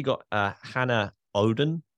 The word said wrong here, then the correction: Auden: If I pronounce that correctly, it's Auden, but it's fine.